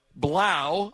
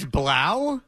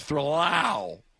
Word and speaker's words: Blau: So after K-Razor comes Blau, although it's spelled three Lau. – That's Blau? – That's Blau. That is supposed to be a B, but it's a three. It's Thrlau. Blau.